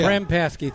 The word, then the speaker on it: Krempaski: Krempaski. – I'll butcher that. – Yeah. Krempaski, three syllables.